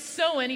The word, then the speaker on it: Samhain: So it's pronounced Samhain, even though it looks nothing like Samhain.